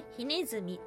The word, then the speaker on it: 火鼠: 漢字そのまま、ひにネズミと書いて、ひねずみっていう風に読むんですけども、これ有名なもので、ありますね。はい。竹取物語に登場するひねずみの衣っていうものがあります。